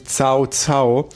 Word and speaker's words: Cao Cao: Das würde ich nun mal eigentlich Kakao aussprechen, aber es wird wirklich Cao Cao.